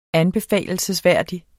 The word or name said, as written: anbefalelsesværdig